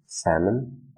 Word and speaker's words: salmon: Notice, note that we do not pronounce the letter or sound l. We don't say salmon, no, just salmon, salmon. Even though we write l, we don't say it, we don't pronounce it.